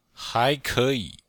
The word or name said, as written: hai2ke3yi3